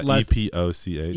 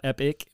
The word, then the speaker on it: epoch: Anyway, is that E P O C H? – E P O C H. Epoch. Epoch. Sorry, yeah. Not epic, but epoch. – Epoch. – Epoch. That is how you say that.